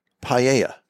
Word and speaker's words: paella: It's spelled really weird. P-A-E-L-L-A. – Yeah, yeah. Paella. – Paella? – Paella. – There's no L. Paella. – Two L's together makes a Y sound.